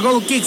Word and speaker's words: goal kick: Lo dije bien, gol kick. – Sí, señor, goal kick, saque de meta.